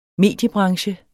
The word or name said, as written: mediebranche